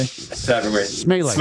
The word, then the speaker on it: Smeele: Smeele. – Smeele. – Smeele. – Yeah, like Michael Saylor. – It's Dutch. It's Smeele.